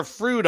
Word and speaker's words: Froude: Not food, but Froude, right? We're going to get our Froude on, and that's how you say it. Don't be rude. Make sure you pronounce it right. It's Froude.